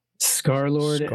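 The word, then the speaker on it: Scarlxrd: Scarlord is spelled with the X as an O. The O is an X. It's as S-C-A-R-L, Scarlord, L-X-R-D.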